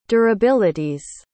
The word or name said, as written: durabilities